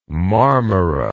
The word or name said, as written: Marmara